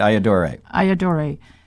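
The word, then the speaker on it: Ayyadurai: And I invited Dr. Shiva Iyadure. Am I saying it correct? – You said it right. Iyadure.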